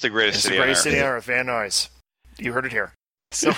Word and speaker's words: Van Nuys: And that's Van, how do you say that again? – Van Nuys. Yeah. – Van Nuys. – Van Nuys, California.